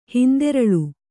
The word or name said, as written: ಹಿಂದೆರಳು